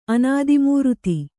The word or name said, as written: ಅನಾದಿಮೂರ್ತಿ